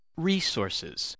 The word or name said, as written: resources